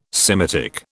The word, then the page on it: Semitic: British English